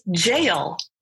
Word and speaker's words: gaol: But it is actually pronounced as jail, which is something that my dumb American ass would not be able to know looking at it when you spell it G-A-O-L.